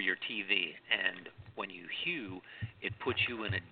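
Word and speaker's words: HU: And it's pronounced like the name Hugh, H-U-G-H, Hugh, or H-U-E, Hugh.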